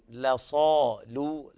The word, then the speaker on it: لصال: أيضا لصال الجحيم، لصال لام مرققة والأولى أيضا مرققة، بل لامين مرققات في هذه الكلمة لصال الجحيم.